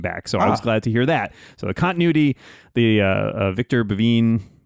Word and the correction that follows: Bevine: I mean, he's saying his name wrong. Bevine, who did the narration on the Bayern Agenda and Aleph Extraction, will be doing the Nova Incident book.